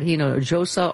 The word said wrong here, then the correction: Hinojosa: You pronounced the J. – Don't pronounce that. – Pardon me. – You know, it's an H sound. – Pardon me. – Like San Jose.